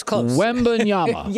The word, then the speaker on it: Wembanyama: We got to work on the pronunciation, but close. – Wembanyama. – Yes, there you go.